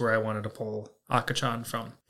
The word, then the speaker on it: akachan: Today's Lance's Lessons is going to be the Japanese word for baby, and that is akachan. That is spelled A-K-A-C-H-A-N.